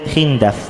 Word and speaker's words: خندف: الشاهد على فضلك، أعلى مكان من نسب خندف، خندف بكسر خندف بكسر الخاء والدال، خندف،